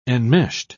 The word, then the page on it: enmeshed: British English